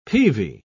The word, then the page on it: peavey: British English